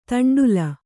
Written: ದಂಡ್ಲೆ

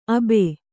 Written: ಅಬೆ

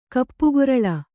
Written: ಕಪ್ಪುಗೊರಲ

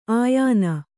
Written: ಆಯಾನ

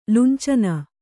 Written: ಲುಂಚನ

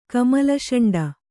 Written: ಕಮಲಷಂಡ

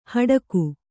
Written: ಹಡಕೆ

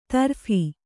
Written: ತರ್ಫಿ

